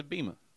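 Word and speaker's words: Abema: Both Raw and SmackDown will air on, forgive me if I say this wrong, Abima? Is it Abima? – I think it's Abima.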